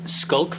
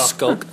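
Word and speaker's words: Schalk: Skulk van der Merwe, apparently. Lou Luke was saying we've been pronouncing it wrong all this time. – Oh. – Skulk. So there we are.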